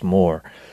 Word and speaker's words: mort: Mortal sins, and it comes really from that French word for death, mort, M-O-R-T, but it's pronounced more.